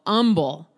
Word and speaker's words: Humble: It was in a town called Humble. That's how they pronounce the word H-U-M-B-L-E there in Texas, just to give you an idea.